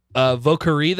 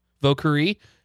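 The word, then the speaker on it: Vieux Carré: No, I did get on the Vocari though. I don't know. I think that's how it's pronounced. Vocari. And also. – Spell it. – Well, I think it's V-I-E-U-X C-A-R-R-I-E.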